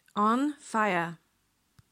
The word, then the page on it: on fire: slow